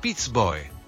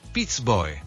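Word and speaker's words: Piz Boè: Wie spricht man den aus? Pizboi? Pizbö? – Pizboi. – Wie nochmal? – Pizboi. – Pizboi, also auf dem Pizboi.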